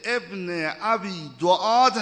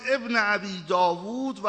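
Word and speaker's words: ابن ابی دؤاد: هم زرقان گفتن، هم زرقان، هم ابن ابی داوود و هم ابن ابی دعاد. اینا 4 نفر نیستن، اینا 2 نفرن. 2 جور اسمشون تلفظ شده.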